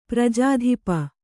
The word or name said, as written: ಪ್ರಜಾಧಿಪ